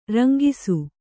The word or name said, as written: ರಂಗಿಸು